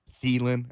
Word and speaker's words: Thielen: Minnesota, Dalvin Cook, Adam Thielen, as his name is pronounced, Stephon Diggs.